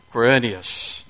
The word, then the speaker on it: Quirinius: This census first took place while Quirinus, that's why y'all didn't want to read it with me. You didn't want to have to pronounce that name. You left it for me. Quirinius, there we go, was governing Syria.